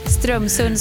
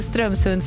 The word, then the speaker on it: Strömsund: Det har kliat i mig nu en hel timme sedan Lisa Friberg försökte uttala Strömsund. Så jag säger ja, det nu, Strömsund, Strömsund, Strömsund. – Precis, det är så det ska låta och ingenting annat.